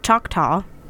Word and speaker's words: Choctaw: The name Tombigbee comes from Choctaw. I hope I say that right. – It is.